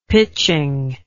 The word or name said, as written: pitching